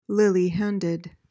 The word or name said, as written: lily-handed